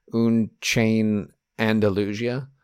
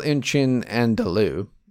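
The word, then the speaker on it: Un Chien Andalou: But it's called In Chin Andalou. He's kind of mispronouncing it, like he's adding some flair to it there.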